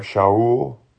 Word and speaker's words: Shaul: Which, that sounds really similar to us, but we actually have a wrong pronunciation. I think Saul is more like Shaul.